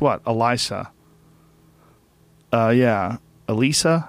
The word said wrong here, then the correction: Elisha: Elisha, Elisha, Elisha.